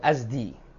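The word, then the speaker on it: Azd: Azad bacanya, zainya disukun, ya, minal azdi, ya, minal azad, ya, Adam Tiwal yang berkulit sawo matang.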